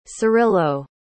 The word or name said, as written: cirilo